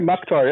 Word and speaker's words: Mukhtar: Yeah, Mukhtar, yeah, from Germany, Turkish ties.